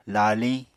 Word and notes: laaliñ: Apatani

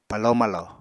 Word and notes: palo-malo: Apatani

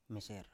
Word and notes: meser: Biak

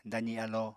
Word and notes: dazi alo: apatani

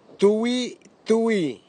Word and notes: tuitui: Nafe; Tanna

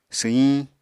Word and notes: swyiñ: Apatani

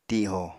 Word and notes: tiho: Apatani

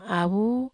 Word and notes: ai avu: Wamesa

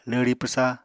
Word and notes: lwri pwsa: apatani

